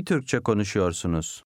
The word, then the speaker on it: konuşuyorsunuz: The U before the Y disappears. – İyi Türkçe konuşuyorsunuz.